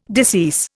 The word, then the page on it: decease: Indian